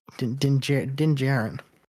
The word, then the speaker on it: Din Djarin: Din Djarin. – Okay, yeah. I always forget how to actually say that. Din Djarin.